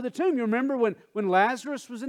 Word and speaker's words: Lazarus: That cracks me up that I just said Lazarus, because sometimes the old timers would say Lazarus. It's really Lazarus. Let's say that together just so we're all phonetically correct. Lazarus. There is no Lazarus. Okay, it's Lazarus. Us.